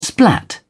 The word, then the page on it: splat: British English